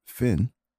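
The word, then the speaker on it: Fionn: The band's name is pronounced Finn, but because it is spelt with F-I-O-N-N, I had the hardest time trying to figure out how to spell their name. I had to go on YouTube and do the how to pronounce name thing. But it's pronounced Finn, and the song is titled Dirty Dancing.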